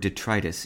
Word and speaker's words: detritus: If you use the word detritus in speech, remember to stress the second syllable, as you do in the words arthritis and bronchitis.